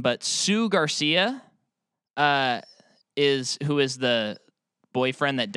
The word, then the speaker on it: Jsu: I looked up two different things, and one of them said Jackson State University. Like, and that's not referring to him. That was seriously a pronunciation thing that I saw. And then the other one was JSU Garcia. And it's like, nope. – Oh, okay. – Both wrong.